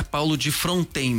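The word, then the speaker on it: Paulo de Frontin: Só para registrar, os moradores locais e das antigas costumam pronunciar Paulo de Fronten mesmo, apesar de escrever Frontin.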